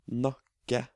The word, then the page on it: nakke: Norwegian